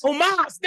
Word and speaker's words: Omos: Is it almost? – Omos? – Omos. There we go.